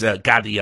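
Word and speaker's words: Gadde: It's Gaddy, I learned now. I mispronounced their name loads on all the other videos, but it's Gaddy, like that guy who says these nuts.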